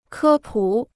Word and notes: 科普: kē pǔ

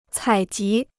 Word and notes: 采集: cǎi jí

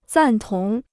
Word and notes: 赞同: zàn tóng

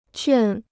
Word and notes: 劝: quàn

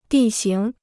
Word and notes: 地形: dì xíng

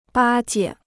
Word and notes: 巴结: bā jie

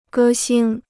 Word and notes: 歌星: gē xīng

